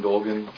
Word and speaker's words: Dolgin: Cecilia, C-E-C-E-L-I-A. My last name is Doucette, D-S, and David, O-U-C-E-T-T-E. – Jean Dolgan, G-E-N-E. Last name, D-O-L-G-I-N. – Joe Solid, J-O-S-O-L-E-T. – Rena Kirsch, R-E-N-A, K-I-R-S-C-H.